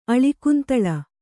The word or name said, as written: ಅಳಿಕುಂತಳ